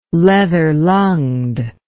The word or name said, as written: leather-lunged